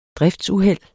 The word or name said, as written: driftsuheld